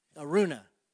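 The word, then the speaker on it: Araunah: And trying to remember his name, Aaron, Aruna, yes, is that right? Did I say it right? Yeah,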